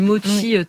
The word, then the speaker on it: mochi: Est-ce que je prononce bien d'ailleurs? – Oui, mochi. Bon.